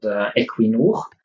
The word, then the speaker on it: Equinor: Yes, so you pronounced the company name correctly. It's we are called Equinor.